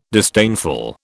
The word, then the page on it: disdainful: British English